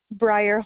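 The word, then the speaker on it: Bryar: Briar Horn. – Can you spell that? – B-R-Y-A-R-H-O-R-N.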